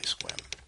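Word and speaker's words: Sequim: No, it's actually Squim. It's spelled not like it sounds.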